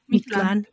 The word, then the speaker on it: Mictlan: I really am loving the portrayal of how the spirits look when they're in Mictlan. Am I saying that right, Jen? – Yeah. – Mictlan. Okay, good.